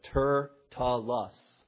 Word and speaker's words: Tertullus: And yes, that is the way you're supposed to pronounce it. Tertullus. Sounds like turtle, doesn't it? But there's the pronunciation.